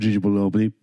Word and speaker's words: Anunoby: Yeah, it's actually pronounced OG Anunoby.